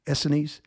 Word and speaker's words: Essenes: Essenes.